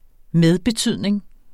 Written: medbetydning

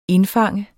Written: indfange

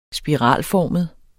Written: spiralformet

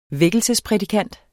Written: vækkelsesprædikant

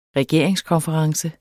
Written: regeringskonference

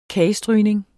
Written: kagstrygning

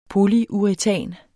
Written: polyuretan

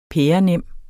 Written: pærenem

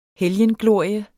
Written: helgenglorie

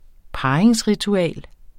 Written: parringsritual

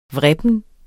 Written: vrippen

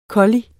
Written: kolli

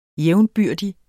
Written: jævnbyrdig

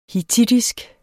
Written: hittitisk

